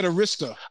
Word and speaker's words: Arista: How'd you pronounce that? – Who? What? – Clive Davis? Arista. – That's Arista. – I'm sure I said it wrong. How do you say you said Arista. – Arista. – And it's Arista? – Arista.